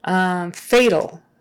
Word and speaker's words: fatal: So that first A is A, a long A, fatal. And that AL is more of an sound again, fatal, just like EL, fatal, AL. You just wouldn't say fatal.